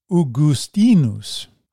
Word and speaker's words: Augustinus: Augustine's Latin name is properly pronounced Augustinus, with the accent on the penultimate syllable.